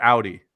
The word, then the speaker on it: Audi: Is it Audi or Audi? – Audi.